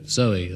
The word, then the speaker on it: Zowie: It's called Kooks and celebrates the birth of a baby on Sunday called Zoe at the moment, spelt, of course, Z-O-W-I-E, to rhyme with Bowie.